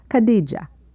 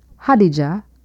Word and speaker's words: Khadija: The title of the song is Khadija, sometimes pronounced Hadija or Khadija, as in North Africa.